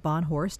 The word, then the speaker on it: Bohnhorst: And if you just joined the program, we're talking today with a wonderful author and a professional in adoption, Tom Bonhorst.